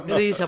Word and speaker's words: RZA: RZA εννοεί το Ρίζα. Α, οκ. – Ρίζα προφέρεται.